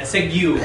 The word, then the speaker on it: segue: A segue, thank you. I mispronounced it, as usual.